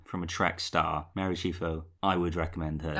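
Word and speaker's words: Chieffo: Mary Chifo, that's how you pronounce her surname.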